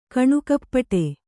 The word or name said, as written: ಕಣ್ಣುಕಪ್ಪಡಿ